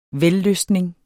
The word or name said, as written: vellystning